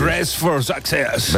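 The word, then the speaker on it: Dressed for Success: La verdad es que es difícil de pronunciar esto, ¿eh? Hazlo tú, que eres más inglés. – Dress for Success. – ¿Ves? A que le sale mucho mejor.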